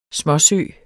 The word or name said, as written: småsø